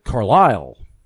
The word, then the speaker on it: Carlisle: And in fact, my own hometown of Carlisle, I just mispronounced it right there. the locals call it Carlisle.